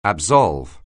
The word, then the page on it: absolve: anglais